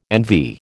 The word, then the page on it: NV: American English